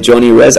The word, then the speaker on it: Johnny Rez: J-R-E-Z Music, como J-Rez. JRZ Music. Y mi nombre es Johnny Rez. A veces, muchas veces en América Latina se escribe la H antes de la O, pero en mi caso lo escribo J-O-H-N-N-Y-R-E-Z. Johnny Rez. – J-O-H-N-Y.